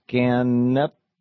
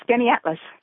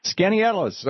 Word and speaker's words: Skaneateles: Felons in Skaneateles, New York. How do you say your town? – Close, Skaneateles. – Skaneateles, okay.